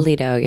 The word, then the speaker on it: Aledo: What kind of town was Aledo? Is that how you say it? Aledo? – Aledo, yeah.